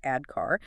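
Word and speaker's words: ADKAR: It's 80-K-A-R, pronounced AD-CAR.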